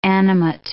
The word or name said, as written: animate